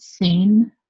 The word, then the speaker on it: Caen: And we went to, um, I think you pronounce it Sane, uh, C A E N.